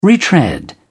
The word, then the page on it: retread: British English